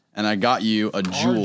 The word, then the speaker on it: Juul: J-E-W-L. – No, this is the way they spell their company. Just like concrete spelled with a K. This one's spelled J-U-U-L.